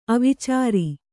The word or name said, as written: ಅವಚಱ